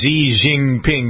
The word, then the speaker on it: Xi Jinping: Number 19 is Xi Jinping.